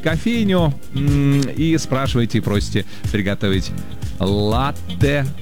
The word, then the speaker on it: латте: И знаете, это правильно, потому что слово итальянское, ударение там стоит на первый слог, латте.